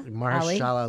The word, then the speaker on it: Mahershala: Mahershala Ali.